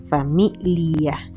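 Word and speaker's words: famiglia: Pero Pero viste que se escribe con una G antes de la L. Se escribe familia, pero se pronuncia familia.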